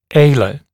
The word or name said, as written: alar